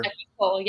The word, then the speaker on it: Equisol: Equisol, yeah. So sol is Spanish for sun.